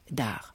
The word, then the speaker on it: Arz: Encore un effort et les voilà qui abordent l'île d'Ar, qui s'écrit A-R-Z. Et attention, on ne prononce pas le Z à la fin.